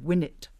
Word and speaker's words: Winnet: This is a bit concerning a heroic young woman called Winnet. Is that how you pronounce that? – Yeah, I think it is, because in the book she's called Winnet E.